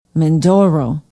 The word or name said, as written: Mindoro